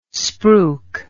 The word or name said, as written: spruik